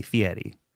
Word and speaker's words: Fieri: It's almost like with a D, Fieri.